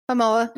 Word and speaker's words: Momoa: Like Jason, okay, don't shoot me if I say this wrong. Jason Momoa, Aquaman. Y'all know who he is, right? – You said it right. Momoa.